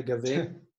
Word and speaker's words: Gaven: So it's Scottish, the way you pronounce the name or spell the name. So it's not traditional G-A-V-I-N, Gaven. It's Gaven. – Oh, okay. – Yeah. – Okay, Gaven.